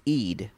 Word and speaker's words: Eid: I've been saying Aid. It's Eid. Sorry, Aid. Eid. Yeah.